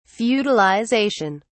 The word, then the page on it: feudalization: British English